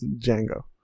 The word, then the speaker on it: Django: It's Django Unchained, but it's not the Django. It's Django. – Oh, the D is silent.